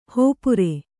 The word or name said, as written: ಹೋಪುರೆ